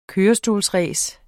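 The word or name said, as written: kørestolsrace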